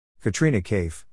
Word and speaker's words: Katrina Kaif: Katrina Kaif, pronounced K-tri-na-K-F, born Katrina Turcotte, July 16, 1983, is a British actress who works in Hindi language films.